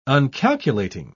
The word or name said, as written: uncalculating